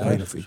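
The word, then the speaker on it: Vejinović: Ik, ehm, ik zou toch opteren om eh, Marco Vianovic erin te zetten. Of Marco Feinovic, zoiets was. – Feinovic, ja. Feinovic. Zo spreek je het En waarom?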